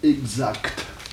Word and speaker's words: exact: Oh, écoutez, actuellement, les deux prononciations existent. Vous ne pouvez pas dire que l'une soit bonne et que l'autre soit mauvaise, voyez-vous. Les deux existent, voyez-vous. Seulement, vous pouvez les juger d'une façon différente. L'ancienne prononciation, si vous voulez, l'ancienne, exact. Quelle est-elle ? – Exact. – Voyez-vous, sans que te.